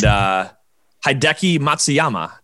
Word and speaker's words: Hideki Matsuyama: So the Masters finished up last weekend, and Hideki Matsuyama.